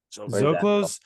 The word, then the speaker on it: Zocalo: Is it Zocalo's? – Zocalo's. So Zocalo's. I really like Zocalo's.